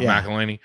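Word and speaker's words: McElhenney: By Rob, how do you say his name? Mackle, the guy from It's Always Sunny. Rob McElhaney or whatever. – Rob McElhaney.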